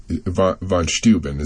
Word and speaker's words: Steuben: So how much did the presence of Lafayette and von Steuben. Is that, am I pronouncing that correctly? – Yes.